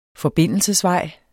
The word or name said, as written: forbindelsesvej